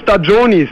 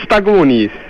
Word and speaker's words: Stagioni: Wir haben nur Stagionis, nicht Stagonis. – Nein, Stagioni, schreibt man G-I-O-N-I.